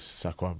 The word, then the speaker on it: Saquon: I mean, if you go to the terribleness of the draft pick that is Saquon Barkley. That is how you pronounce his first name.